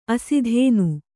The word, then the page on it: ಆಸ್ಥಾನ: āsthāna